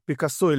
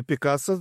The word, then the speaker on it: Пикассо: Потом, значит, некий Пикассо или Пикассо, два ударения в этой фамилии, кстати, как выяснилось, да? на французский и на испанский манер.